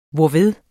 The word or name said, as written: hvorved